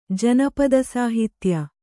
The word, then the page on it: ಜಾನಪದಸಾಹಿತ್ಯ: jānapada sāhitye